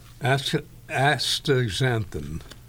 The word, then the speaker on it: astaxanthin: Astaxanthin. There's no G in there. It's astaxanthin. A-S-T-A-X-A-N. The X is pronounced Z. Astaxanthin.